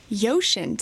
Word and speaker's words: Uxin: It is spelled UXIN, so the pronunciation there might be a little different than people were expecting, but it's the largest used car dealer in China.